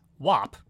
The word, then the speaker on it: WAP: Cardi B's new single, and I am pronouncing it the way I've been told it's pronounced, WAP, featuring Megan Thee Stallion, debuts straight in at number one.